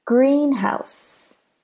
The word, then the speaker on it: greenhouse: Another example is greenhouse. Greenhouse. Greenhouse. Do you hear how green is stressed? It's louder, longer, more emphasized than house. House is shorter.